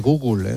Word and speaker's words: Google: Ahora es verdad que se dice Google, ¿eh? – Google. Pues gracias por la pronunciación. Google. – Sí, no sé por qué decimos esa E ahí, Google.